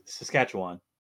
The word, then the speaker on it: Saskatchewan: Is it Saskatchewan? – It's Saskatchewan.